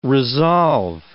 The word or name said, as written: resolve